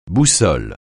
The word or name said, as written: boussole